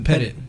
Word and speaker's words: Pettit: Is it an E at the end or no? – It's no. So it's Pettit.